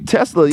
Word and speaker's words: Tesla: A Tesla, am I saying it right? – Tesla, yeah.